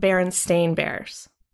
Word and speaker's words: Berenstain: It's always been spelled with an A and it's pronounced Berenstain Bears.